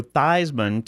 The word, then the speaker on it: Theismann: How about changing the way you pronounce your name to Joe Theismann to rhyme with Heisman?